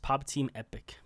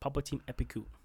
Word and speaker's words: Pop Team Epic: Yeah, it's unusual how you spell it. It's just Pop Team Epic. But Papu Team Epiku.